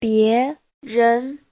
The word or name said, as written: bie2ren2